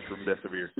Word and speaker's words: remdesivir: Remdesivir, your death is near. That's how you pronounce it.